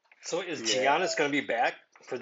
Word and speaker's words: Giannis: It's Giannis. It's just like a zero. – It's a silent G, I think.